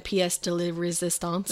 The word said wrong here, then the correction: pièce de résistance: The pièce de résistance. – That, thank you. I added too many, like, laws.